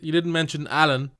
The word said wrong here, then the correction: Alun: No. No, I mentioned Wynn Jones. – Alun, as it's spelled.